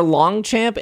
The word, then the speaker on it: Longchamp: Just look at Longchamp and Clarks, for example. These are brands you wouldn't immediately associate with Pokemon. That's true. That's yep. – Those are two very good examples. Our Longchamp